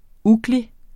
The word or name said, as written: ugli